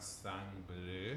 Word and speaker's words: Sang Bleu: And they've got Sang Bleu, which I believe is how the French pronounce it. – Yep.